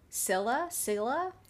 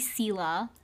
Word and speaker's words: Scylla: The sky and sea are both stormy, and they're heading straight for the entrance, which is between Charybdis and her sister Scylla. – It's supposed to be Scylla.